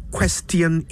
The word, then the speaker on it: question: Q-U-E-S-T-I-O-N. I'll give you a hint. It's not question. It's not question either. It's question.